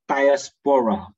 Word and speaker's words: diaspora: 那么那个词，希腊文那个希腊文呢，那么它发音呢是叫 diaspora 哈，diaspora 就是讲 dispersion，the dispersion 的，就是那个散居的意思。那么我们知道呢，在这个在这个这些的散居的人中间传福音。